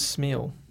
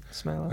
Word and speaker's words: Smeele: So spelt S-M-E-E-L-E, but... – Yeah, because I always thought it was Smeele. – Yeah, and I guess it is, but it's the way it's pronounced in the Netherlands is... – Smeele. – Like Smeele, so...